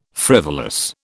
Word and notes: frivolous: British English

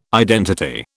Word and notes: identity: British English

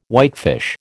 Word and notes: whitefish: American English

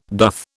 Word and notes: doth: British English

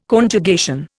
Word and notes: Conjugation: Indian

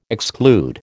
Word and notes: exclude: American English

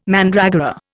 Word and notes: mandragora: Indian

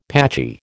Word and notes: patchy: American English

